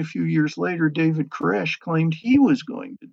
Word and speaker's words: Koresh: And Cyrus, in Hebrew, is pronounced Koresh.